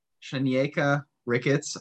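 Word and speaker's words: Shanieka Ricketts: So our next athlete we want to talk about is, hope I'm saying this right, Shanyeka Ricketts.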